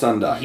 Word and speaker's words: Undyne: I'm pretty sure it's pronounced Undyne. – U-N-D-Y-N-E, right? – Yeah. – Undyne.